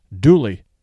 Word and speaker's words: Dula: What many people don't know is that song is connected to a true story of a North Carolina man named Tom Dooley, spelled Dula, D U L A, but pronounced Dooley, who lived on the Yadkin River in Wilkes County, North Carolina, back in the 1860s when this story takes place.